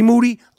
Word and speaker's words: Moody: But first, me and G Moody, last name rhymes with duty, are going to do our thing.